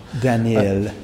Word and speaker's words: Daniil: That can't be the pronunciation. – Daniel. – Yeah, that's it. You've got to run it together. – Yeah. – I think he's... – It's pronounced D-A-N-I-I-L.